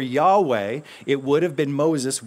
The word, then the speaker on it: Yahweh: Now, in English, we often say Jehovah. In Hebrew, they didn't have the phonetic Jah, so they would not have said Jesus. They would have pronounced that differently. They would not have said Jehovah. They would have pronounced it Yahweh.